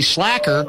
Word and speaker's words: slaker: It's spelled S-L-A-K-E-R. And what's funny about it is, Slaker. – I always thought it was pronounced Slaker, and then somebody, some linguist said, no, it's actually Slacker.